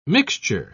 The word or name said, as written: mixture